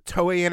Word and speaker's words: Toei: Marvel hired two companies, the Japanese-based Toei Animation. Did I say that right? – Yeah, you know, oh, Toei Animation in the 80s?